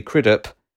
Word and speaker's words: Crudup: Russell, the lead guitarist played in the finished film by Billy Crudup, was originally cast to be played by Brad Pitt.